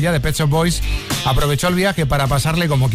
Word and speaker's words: Pet Shop Boys: Y gracias porque hiciste que el Tecnopop de los 80 nos sonara muy familiar, a pesar de que al descubriros aún no sabíamos pronunciar muy bien lo de los Pet Shop Boys, decíamos todo el mundo Pechos Boys.